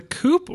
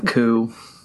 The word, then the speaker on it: Coup: So, is it the Coop or is it the Coup? – I thought it's the Coup. – The coup, yeah. Sorry. I have an issue not pronouncing that P sometimes, so.